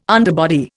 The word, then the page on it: underbody: Indian